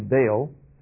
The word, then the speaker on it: Baal: Well, of course, Baal, we pronounce it usually Baal, meant Lord.